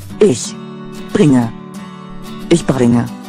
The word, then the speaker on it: Ich: Ich is the German word for I. Please notice that it doesn't sound like ich, I saw a mouse. It has a softer ending, like letting the air out of a tire.